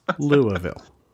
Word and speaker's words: Louisville: but they pronounce it Louisville. It's Louisville. I was told that I need to correct it on the show, so here I am doing it. Louisville.